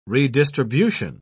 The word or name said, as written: redistribution